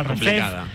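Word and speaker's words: RFEF: Bueno, yo creo que cuando se creó la categoría, como era la abreviatura de Real Federación Española de Fútbol, la gente empezó a pronunciar esa abreviatura ref. Como buenamente podría. Pero sí, claro, sería ref. Otros decían REF, así que el nombre, para que nos entendamos todos en estas categorías: primera federación, segunda federación, tercera federación.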